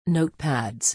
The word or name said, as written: notepads